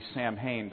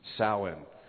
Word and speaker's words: Samhain: which is spelled like it would be Samhain, but the actual Celtic pronunciation would be Samhain.